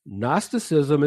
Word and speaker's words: Gnosticism: Gnosticism is spelled G-N-O-S-T-I-C-I-S-M, Gnosticism.